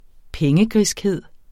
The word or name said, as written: pengegriskhed